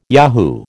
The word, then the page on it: yahoo: American English